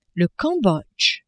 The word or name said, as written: Cambodge